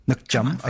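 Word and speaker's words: nước chấm: Okay, so actually, you mentioned, how do you pronounce it? Nookcham? Nookcham, okay. – Yeah, there you go.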